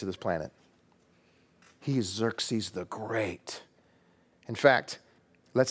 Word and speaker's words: Xerxes: You have to say it like this. Xerxes.